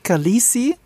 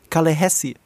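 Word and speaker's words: Khaleesi: Also in Dothraki heißt es nicht Kalisi, so wie es geschrieben wird, sondern eigentlich müsste es Kalehesi heißen.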